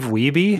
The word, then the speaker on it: Wiebe: Oh, Steve Wiebe? – Yeah, Steve Wiebe.